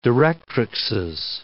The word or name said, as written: directrixes